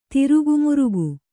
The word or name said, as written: ದುರುಗಮುರುಗಿ